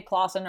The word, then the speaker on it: Clason: Actually, people always say Clason or Clason. And really? – Clason. I'm like, so that's my married name. My maiden name is Foster. And nobody ever mispronounced that name. And you'd think like Clason is like an easy enough name, but people say it wrong all the time.